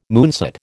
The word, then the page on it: moonset: American English